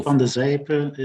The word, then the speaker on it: van de Sijpe: Now, can I just check, how do you pronounce your last name? I pronounced it as Sijpe, but is that quite right, or am I a little bit off? – No. Marc van de Sijpe is the correct pronunciation.